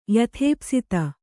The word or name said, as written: ಯಥೇಪ್ಸಿತ